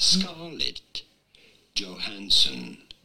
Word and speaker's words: Scarlett Johansson: Scarlett Johansson.